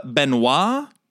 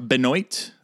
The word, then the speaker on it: Benoit: And Drowning on Dry Land is a song by Tab Benoit. I think it's French. – Yeah, it's for sure French. – Or Benoit, if you want to pronounce it the English non I mean, he's a country artist.